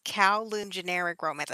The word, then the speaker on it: Kowloon Generic Romance: It is, I think I know how to pronounce this, and this is one that we talked about. It's Kowloon Generic Romance. I think that's how you pronounce that. Is that how you pronounce yeah. – it? – Yeah.